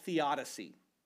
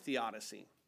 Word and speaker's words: theodicy: Well, there's a term in Christianity called theodicy. T-H-E-O-D-I-C-Y, theodicy. Some people say it's theodicy, but it's not. It's theodicy is how it's pronounced.